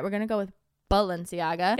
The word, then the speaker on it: Balenciaga: Also, is it pronounced Balenciaga or Valenciaga? – I thought it was Balenciaga. – Me too. But sometimes I swear I hear people say Valenciaga with like a V.